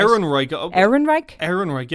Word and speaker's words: Ehrenreich: Alden Ehrenreich, I hope I've said that right. – Ehrenreich, yeah. E-H-R-E-N-R-E-I-C-H.